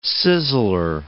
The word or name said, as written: sizzler